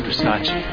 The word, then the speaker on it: Versace: Versace. It's pronounced Versace.